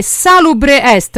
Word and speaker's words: Salubre Est: Ci andiamo ad ascoltare Salubre Est. Ho detto giusto? – Giustissimo.